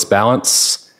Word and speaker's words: Ballance: Yes, my name is Nicholas Balance. Last name is spelled B-A-L-L-A-N-C-E, and I'm a special agent with the Federal Bureau of Investigation.